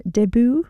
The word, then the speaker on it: debut: Can I also just say as well, I'm really enjoying the fact that you are adamant that you use the word first time out in its correct French pronunciation. I can't say pronunciation. Debut. Every time.